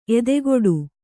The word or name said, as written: ಎದೆಗೂಡು